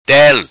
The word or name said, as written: Tel